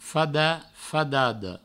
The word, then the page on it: fadat, fadada: Provençal; fada, fadado